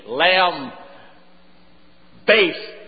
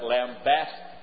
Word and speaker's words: lambast: How do you pronounce L-A-M-B-A-S-T? Lamb. Based. That's right. There's no dictionary in the land that will pronounce that lambast. I don't know whether I ever heard a preacher pronounce it correctly. It is lambast.